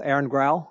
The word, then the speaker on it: Aaron Grau: Today, we're going to be talking with Aaron Grau.